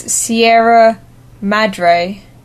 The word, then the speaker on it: Sierra Madre: Sierra Madeira, as in the cake. – What? – Oh, it's Sierra Madre. Fine, I'll just call it Madeira. It's a cake. Let's leave it at that. – What? – She's saying I pronounced it as in Madeira, which is a type of cake.